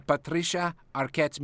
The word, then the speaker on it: Patricia Arquette: A Patricia Arquette's mediums. – Well done, Pepe. Somewhere Patricia Arquette has sensed that you have pronounced her name correctly.